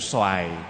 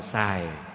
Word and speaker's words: xoài: Đúng ra thì phải là đủ xoài, người đủ xài, người Nam đọc là xài, đúng ra là xoài, mà nếu cầu dừa đủ xoài là còn chết nữa.